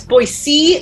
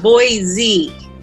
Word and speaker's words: Boise: It's Boise, not Boise. – Oh. – Wow. Well, we're saying it wrong. – It's Boise. – Keep your children Boise. – Right. Boise, Idaho.